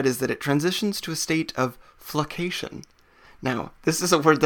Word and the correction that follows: flocculation: Flocation, F-L-O-C-C-O, I said it wrong. Flocculation, it's even better.